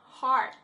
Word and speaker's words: heart: 比如说呢像这个 hear，我们刚才已经讲过了，是怎么样，是听到，后面呢再加一个 t 就变成心 heart，注意一下它的发音哦。这边的 er 是 ear，不是当 ear，是当 r 的声音，所以是 heart。